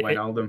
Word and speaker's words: Wijnaldum: So, just before we go, um, thoughts on the, the potential that um, uh, Georginio Yeah, I, I, can never pronounce his last name. – Mm-hmm. It, Wijnaldum. – Wijnaldum. Yes, I always want to yeah.